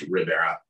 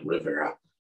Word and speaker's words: Ribera: I guess I'll start with so in case you're wondering, the pronunciation for my last name is Rivera. It's just like Rivera.